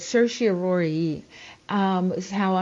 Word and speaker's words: certiorari: And I spell that C-E-R-T-I-O-R-A-R-I. Certiorari. Some pronounce it certiorari, but certiorari is how I'm going to pronounce it today, because that's the way the majority of people do pronounce it.